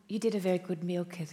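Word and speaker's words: Mielke: whose name was Milke, is that how you pronounce it? – Yes.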